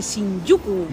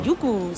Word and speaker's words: Shinjuku: el barrio de Shinjuku. – Muy bien pronunciado. – Shinjuku, sí señor.